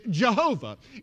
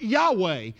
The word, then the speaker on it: YHWH: But YHWH is pronounced in some dialects of Hebrew is Jehovah. In others, it is pronounced Yahweh. In some, it is pronounced Adonai.